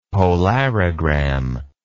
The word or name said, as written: polarogram